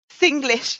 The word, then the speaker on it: Singlish: All the, is it, do you pronounce it? Is it Shinglish? Singlish? – Singlish, yes.